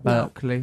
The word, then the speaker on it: Berkeley: Berkeley.